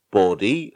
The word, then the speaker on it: Bode: I was pronouncing it Bode. And then I went onto the wiki and saw it was pronounced body. And I was thinking, that's not right. That's not how you spell body.